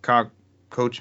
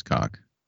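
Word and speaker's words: Koch: So you have the Gearbox Entertainment, you have Coach Media. – Coach. – It's German. I think it is pronounced cock.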